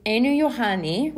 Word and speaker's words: Einojuhani: Okay, it's a sort of a story of relays and almost missed opportunities but the composer is a Finnish composer, so in order to know how to say his name, you have to have some experience with Finnish. And fortunately, through my colleagues, I have learned how to say his name. It's Einojuhani, which is his first name, and the final his his last name is Rautavaara.